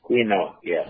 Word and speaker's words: quinoa: She is using quinoa. Is that how you say it? – Quinoa, yeah. – Quinoa. Q-U-I-N-O-A.